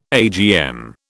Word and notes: AGM: British English

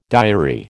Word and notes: diary: American English

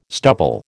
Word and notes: stubble: American English

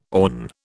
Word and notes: awn: British English

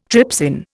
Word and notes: trypsin: Indian

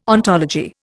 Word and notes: ontology: Indian

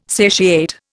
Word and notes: satiate: Indian

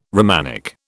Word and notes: Romanic: British English

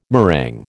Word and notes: meringue: American English

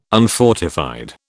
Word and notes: unfortified: British English

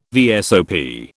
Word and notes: VSOP: British English

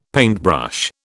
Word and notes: paintbrush: British English

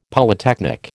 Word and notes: Polytechnic: American English

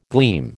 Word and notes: gleam: American English

